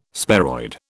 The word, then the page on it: sparoid: British English